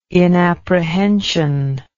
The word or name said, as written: inapprehension